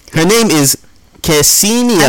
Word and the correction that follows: Ksenia: I don't think you pronounced the K. – Ksenia Ovchekinokova.